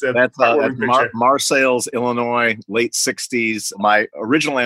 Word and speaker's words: Marseilles: But that's just me in front of the the ancestral Morello home in Marseilles, Illinois, uh, spelled like Marseille, France, pronounced Marseilles because it's in central Illinois.